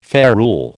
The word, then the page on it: ferule: American English